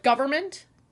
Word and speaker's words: government: Do you pronounce the N in government? – I do not. – I feel like I do super fast. So is it like government? Government. – I just say government.